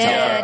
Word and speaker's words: Telfer: You can join cast members Lauren Coslow, Paul Telfer, Melissa Reeves, did I pronounce his name right? – You did. Telfer. Okay. Yes.